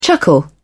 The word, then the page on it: chuckle: British English